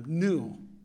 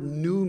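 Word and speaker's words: nu: Yeshua said to them, new. Okay, that's in the complete Jewish translation, Jewish Bible. And I'll get into what that word new means.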